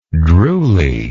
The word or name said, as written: drooly